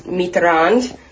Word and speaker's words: Mitterrand: We definitely saying that wrong. Mitterrand, the former president of France.